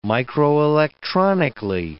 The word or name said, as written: microelectronically